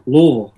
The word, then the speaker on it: Louisville: Did I say it right? – That's right. Louisville.